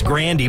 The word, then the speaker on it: Grande: I saw Ariana doing an interview one time, and we actually pronounced her last name wrong. It's Grandy, but Grande is a thing, so she gets that.